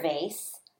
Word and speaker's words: vase: Vase has a long A. A long A says what? A, you got it. Long A says A.